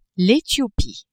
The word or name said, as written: Ethiopie